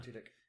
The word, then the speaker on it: Tudyk: the Alan Tudyk? – Yeah, that's right. – Yeah. – Alan Tudyk. – They're both in it, so hopefully it'll be good, but we'll see. – Alan Tudyk is amazing.